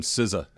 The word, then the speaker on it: SZA: Start, I play Big Boy from SZA. Did I say that right? – Yes, yeah, you did. You did.